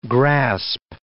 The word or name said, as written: grasp